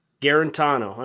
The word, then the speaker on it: Guarantano: How do you say his last name? – Guarantano. – I almost said Guantanamo.